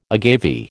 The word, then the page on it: agave: American English